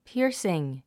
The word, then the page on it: piercing: píərsiŋ; ピアスィン